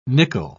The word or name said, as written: nickle